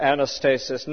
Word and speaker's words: anastasis: I suppose if you wanted to give an English pronunciation to them, they are anastasis nekron.